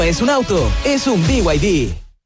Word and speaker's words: BYD: ¿BID? ¿No es BID? – Nada que ver. Se escribe BID, pero se dice BYD.